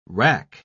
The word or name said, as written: rack